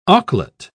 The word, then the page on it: auklet: British English